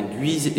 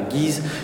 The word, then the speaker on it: Guise: On prononce bien Guise et surtout pas Guise.